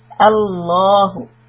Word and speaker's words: الله: So the middle lam is now being preceded by a fatha, so the lam will be pronounced heavy. Allahu.